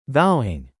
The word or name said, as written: vowing